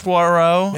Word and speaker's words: Poirot: It's the third installment of I Never Pronounce the Poirot. Is that? – Poirot. – Yeah. – Hercule Poirot.